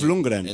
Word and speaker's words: Lundgren: Dolph Lundgren.